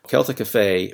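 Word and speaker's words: Celtic Afae: Celtic Affay brings magic to kids of all ages.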